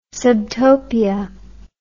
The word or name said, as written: subtopia